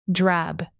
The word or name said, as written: drab